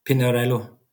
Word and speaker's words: Pinarello: It's the Italian Pinarello. – Pinarello.